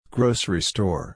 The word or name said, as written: grocery store